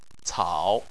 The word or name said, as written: cao3